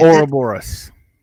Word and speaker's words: Ouroboros: Well, and evidently he is quite old, And time doesn't really affect him down there, it seemed like, because his name, Ouroboros, or Rober, I don't even know how to say it. Ouroboros. – Ouroboros, in mythology, is actually the snake that's eating its own tail.